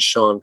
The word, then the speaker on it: Chaon: Well, I'm currently reading a book that's coming out, I think, next April by Dan Shaun called Sleepwalk. And Dan Shaun, it's spelled Chaon.